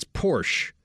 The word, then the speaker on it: Porsche: It's not Porsche, it's Porsche. There is no ah uh at the end.